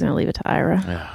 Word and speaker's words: Ira: Ira rhymes with Myra.